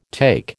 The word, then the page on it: take: American English